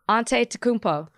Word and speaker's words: Antetokounmpo: Antetokounmpo.